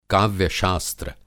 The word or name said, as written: काव्य-शास्त्र